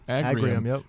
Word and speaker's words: Agrium: Potash Corp, which is the world's biggest crop nutrient company, and Agrium, am I pronouncing that correctly? – Yep. Agrium, which is the largest farm retailer in North America.